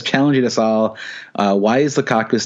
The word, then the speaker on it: Lukaku: Even though Lukaku rhymes with seasonal flu, Big Rom did not have the flu this weekend, and he banged in 4 goals.